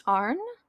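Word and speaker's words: Auryn: I think his last name is pronounced Arn. Arn.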